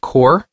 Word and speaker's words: Qore: One more piece of info on the PSN for this week is that if you go download episode 5 of Core, that's spelled Q-O-R-E. – O-R-E.